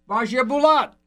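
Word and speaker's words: Basia Bulat: Do you know the pronunciation of Bazia Bulat? – Bazia Bulat. – Thank you. – Perfect.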